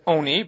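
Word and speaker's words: oni (עֹנִי): We would have said that it is ani. But we have a misora to pronounce it as oni, but it's spelt as an ani.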